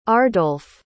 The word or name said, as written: ardolph